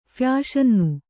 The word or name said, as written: ಫ್ಯಾಷನ್ನು